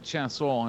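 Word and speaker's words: Chiasson: who I believe pronounces it Chasson, and I've had enough of this shit.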